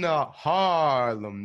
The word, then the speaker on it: Haarlem: I'm in Haarlem, Netherlands. It's a long A because it's H-A-A-R-L-E-M.